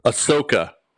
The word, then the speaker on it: Ahsoka: Ahsoka. – Ahsoka. – Ahsoka. – Tano. – Tano. What do you think of this call? Is this like... Because this is outside my realm. – Ahsoka Tano, it was a character that was created for the Clone Wars cartoon.